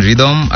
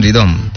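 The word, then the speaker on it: Rythom: তারপর দেখতে পাচ্ছি লিখেছেন রিদম, আই লাভ ইউ। কেমন আছেন রিদম?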